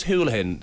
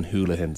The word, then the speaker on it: Houlihan: I mean, this is, and it is Houlihan. This is what I was on with two Johnnies earlier. It's spelled Houlihan, but we put in a call. I think it was the Cork City, his former club, and the man himself confirmed that it is Gavin Houlihan, so that is the correct pronunciation.